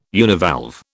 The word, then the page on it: univalve: British English